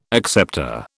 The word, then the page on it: acceptor: British English